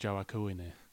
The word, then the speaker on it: Joaquin: Joaquin Phoenix. – I think you'll find it's pronounced Joaquine.